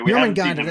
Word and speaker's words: Jormungandr: Yeah, Jormungandr, that's it.